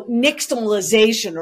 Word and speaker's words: nixtamalization: So nixtamalization